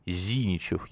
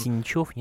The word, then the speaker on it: Зиничев: Вот губернатор Калининградской области Евгений Зиничев. Я надеюсь, я правильно поставил ударение. – Зиничев, нет. – Ну, здесь три варианта, получается, есть.